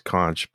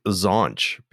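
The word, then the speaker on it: xonsh: It's supposed to be pronounced conch, but my brain says zonch because it's zonch.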